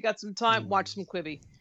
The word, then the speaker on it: Quibi: Am I saying it correct? – Oh, yeah. Quibi? – Yeah, you're saying it right.